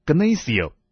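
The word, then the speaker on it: Gnasio (γνήσιος): 在希腊文里面，真这个字读作 Gnasio， Gnasio 这个字就等于是在希腊文中的另外一个字叫做 Philos，亲爱的。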